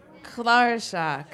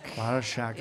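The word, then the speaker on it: cláirseach: Clairshach. Is that how you say it? – Clairshach. – Clairshach. Yes, that's Irish harp.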